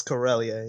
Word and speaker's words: Corellia: It's Corellia, yeah.